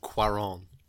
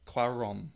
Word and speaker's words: Cuarón: He's pronounced Cuarón. – Cuarón.